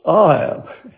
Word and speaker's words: ahab: Another ancient word is ahab, pronounced ahab.